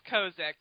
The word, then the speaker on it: Kozik: Yes, it's Kozik.